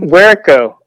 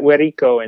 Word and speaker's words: Guercio: Werico. Uh, you would pronounce that Werico in Spanish.